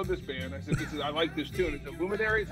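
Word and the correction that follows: Lumineers: How do you say it? – Luminaires. – Luminaires. Luminaires. Sorry.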